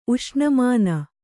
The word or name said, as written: ಉಷ್ಣಮಾನ